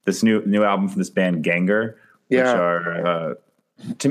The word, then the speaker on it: Gengahr: That's GENGAHR. I believe they're pronounced Ganger.